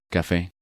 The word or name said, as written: Cafe